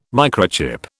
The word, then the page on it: microchip: British English